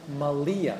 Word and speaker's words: Malia: Maria.